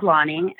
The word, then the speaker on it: Lani: So my name is Lonnie and it's spelled L-A-N-I.